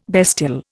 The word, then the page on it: bestial: Indian